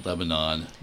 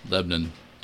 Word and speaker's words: Lebanon: And he's going to be working in Lebanon. And not Lebanon, Lebanon. Two syllables in Indiana.